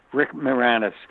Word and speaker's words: Moranis: Well, I've seen the space guy, I can't pronounce his name. You pronounce it. – Rick Moranis? Rick Moranis? – Yeah, Moranis. Rick Moranis.